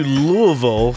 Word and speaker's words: Louisville: I went to Louisville for a music conference.